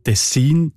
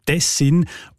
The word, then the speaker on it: Tessin: Das scheint im Fall von Tessin zu Tessin offenbar eben vor allem noch an dieser Region passiert zu sein. Wahrscheinlich, weil man dort schon seit vielen Jahrhunderten Kontakte ins Tessin hat und der Name darum schon so lange im Gebrauch ist, dass er genug Zeit gegeben hat, die deutsche Erstsilbenbetonung anzunehmen. – Ja, und Tessin ist also näher am Original im Tessiner Dialekt und Tessin quasi die eindeutschte Variante.